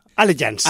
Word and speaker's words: Allegiance: Allegiance. – Allegiance, ah, sí, porque no, si quiero pronunciar la L y después la G, no va a ser.